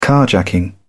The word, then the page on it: carjacking: British English